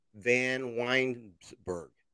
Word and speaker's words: Vanwynsberghe: We are on air with Marsha Vanwynsberghe.